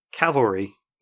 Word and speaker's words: cavalry: He asks, Coulson puts out Calvary tiles. Is he Jesus, if you believe in that sort of thing? Yeah, it's cavalry, not Calvary.